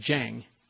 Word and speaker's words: Zheng: And one is Zheng, which is Z-H-E-N-G. Z-H is pronounced a J sound, Zheng.